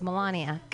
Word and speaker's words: Melania: How do we pronounce her name? Do you guys know? Melania. It's Melania. Good.